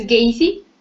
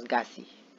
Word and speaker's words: Gacy: ¿No es Gacy? – No, es Gassi. – Gassi, ok, Gassi.